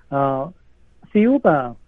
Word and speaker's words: Thiaoouba: Thiaoouba or Thiaoouba, the pronunciation is very similar to Jehovah.